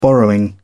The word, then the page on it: borrowing: British English